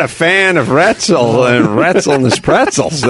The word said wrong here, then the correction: Wetzel's: Wetzel's, I mean.